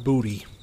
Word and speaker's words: Boutte: This is not the campfire story, but Kayshon, we were saying Kayshon Boutte, and you're telling me I'm wrong. – That is what I'm telling you. It was confirmed after we made a whole scene about it last episode. – So the correct pronunciation you're going to tell me is Booty. – It is 100% Kayshon Booty.